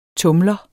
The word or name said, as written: TUMLEREN